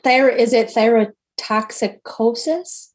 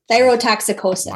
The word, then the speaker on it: thyrotoxicosis: Thyroid storm may be the initial presentation of thyrotoxis. I know I'm not saying that right. Holy God. – Is it thyrotoxicosis? Is good. There a C? – Thank cosis? You. Yes. – Thyrotoxicosis.